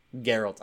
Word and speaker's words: Geralt: Geralt. I haven't. – No, not a hard G. I'm sorry, not Gerald. Not a judge. It's a guga. Kind of like, never mind. – Gug, G-U-G.